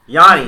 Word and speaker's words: Giannis: How do you say Yanni? – Yanni.